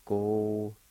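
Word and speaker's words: gohidi: Go-hi-di, go-hi-di. Go-hi-di. Sometimes you can even hardly hear that Y sound. And it sounds like this once again. Go-hi-di.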